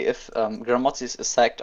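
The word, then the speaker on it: Grammozis: I can't pronounce his name. – Gramozis? – Gramozis, all right, yeah.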